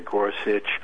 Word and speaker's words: Gorsuch: And I remember the way he, you know, pronounced it. Yeah. It's really Gorsuch.